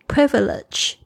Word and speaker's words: privilege: privilege， P-R-I-V-I-L-E-G-E，后面的这个 j 不要念的太重，不要念成 privilege、哦、我有一些学生呢比较倾向每一个字都要念得很重，因为你会发现中文它是这个非常铿锵有力，但是英文呢好像很柔软，好像是音乐的那种感觉。